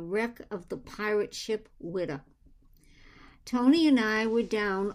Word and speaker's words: Whydah: The museum exhibits are focused on the pirate ship Whydah, pronounced W-I-D-D-A-H, Whydah. That's typical New England.